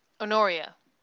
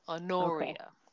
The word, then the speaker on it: Honoria: Honoria. The stress is on the penultimate syllable. Honoria.